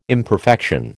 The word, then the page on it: imperfection: American English